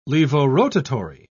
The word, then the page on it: levorotary: British English